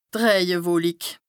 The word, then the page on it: d' Rëjewolik: Bas-Rhin; Strasbourg